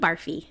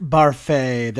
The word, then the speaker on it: Barfée: Next to the microphone is William Barfy. – It's pronounced Barfée. There's an accent aigu.